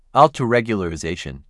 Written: L2 regularization

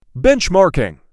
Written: Benchmarking

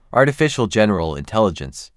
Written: Artificial General Intelligence

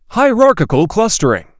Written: Hierarchical clustering

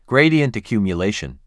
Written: Gradient accumulation

